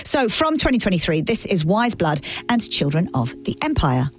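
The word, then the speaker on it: Weyes Blood: Just Wise Blood, and of course that's spelled W-E-Y-E-S, Blood.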